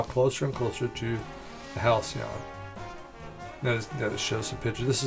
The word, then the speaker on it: Halcyon: Halcyon? – A Halcyon.